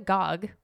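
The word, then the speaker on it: Gág: The oldest American picture book called Millions of Cats by Wanda Gogg, and it's spelled G-A-G, A with a little accent over it. G-A-G, but it's pronounced Gog, allegedly.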